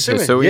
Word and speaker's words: Hisuian: Like for Hisuian? Is that how you say it? Hisuian. – Yeah, you nailed it.